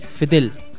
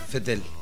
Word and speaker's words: Vettel: Realiza, no, la pronunciación de Vettel. Es Fettel. – Fettel.